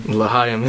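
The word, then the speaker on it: L'chaim: Lachaim is the right pronunciation.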